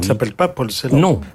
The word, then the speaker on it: Celan: Celan. Dans la graphie roumaine, si on écrit, ça s'écrirait en selle, et c'est à partir de là qu'est forgé le nom Celan.